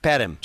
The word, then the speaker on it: Perm: No, it's actually pronounced Perm.